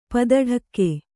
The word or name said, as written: ಪದಢಕ್ಕೆ